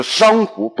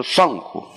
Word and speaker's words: 丧服: 嗯，我这几年我很少看的一部，我可能我《琅琊榜》我看了一遍，《琅琊榜》其他我觉得我都不挑它，但是它里面呢，它把所有的这个，比如说太皇太后的丧丧礼呀，在里面全部读成了丧，这是错误的，是丧礼，不是丧礼，是丧服，不是丧服，因为我觉得我们说文化产品，尤其这种影响很大的这种文化产品。